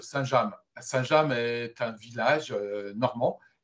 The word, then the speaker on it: Saint-James: Et on prononce bien Saint-James.